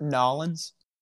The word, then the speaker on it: New Orleans: Pronounced Nolens. – Nolens.